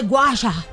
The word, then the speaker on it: gua sha: It's the gua sha.